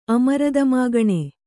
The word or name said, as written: ಅಮರದಮಾಗಣೆ